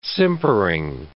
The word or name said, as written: simpering